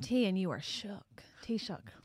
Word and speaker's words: Taoiseach: How do you say the name of what is your head of government? – Taoiseach. – Taoiseach.